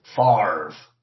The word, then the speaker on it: Favre: Favre. – Why does the R come before the V in your pronunciation but not in the spelling? – Look, it's not tough to be French.